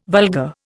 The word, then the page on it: vulgar: Indian